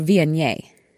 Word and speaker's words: Viognier: Well, I can tell you it's not pronounced as it's spelled, which would be Viognier. Not that, people.